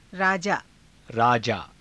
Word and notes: raajaa: Hindi

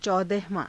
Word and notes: caudahavaa~: hindi

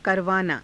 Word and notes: karavaanaa: Hindi

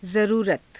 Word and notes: zaruurata: Hindi

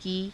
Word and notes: kii: Hindi

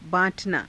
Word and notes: baa~_tanaa: hindi

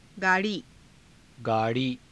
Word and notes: गाड़ी: gaa_rii

